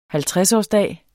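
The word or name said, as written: halvtredsårsdag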